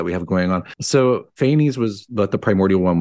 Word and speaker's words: Phanes: is it Phanes? P-H-A-N-E-S? – Yes, Fane.